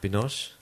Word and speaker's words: Binoche: Yeah, well, it's got quite a famous actress, Juliette Binoche. Is that how you say her name? – Binoche. Yeah.